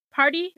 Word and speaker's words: Pardi: Megan Thee Stallion is dating some rapper guy named Party. Party, it's spelled P-A-R-D-I, Party Fontaine.